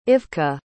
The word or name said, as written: Ivka